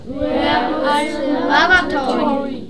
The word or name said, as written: where are the lavatory